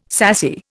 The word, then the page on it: sassy: Indian